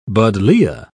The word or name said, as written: buddleia